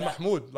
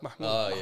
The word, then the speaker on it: Mahmoud: Because usually people don't know how to say my name. No, Mahmoud. Like Mahmoud.